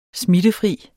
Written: smittefri

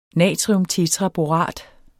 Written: natriumtetraborat

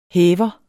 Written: hæver

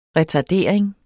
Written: retardering

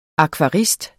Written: akvarist

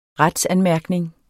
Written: retsanmærkning

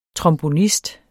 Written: trombonist

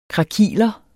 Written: krakiler

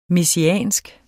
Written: messiansk

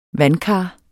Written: VANDKAR

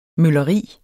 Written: mølleri

